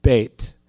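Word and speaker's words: Beit (בית): In Hebrew, the word Beth, which is pronounced Beit, means house of.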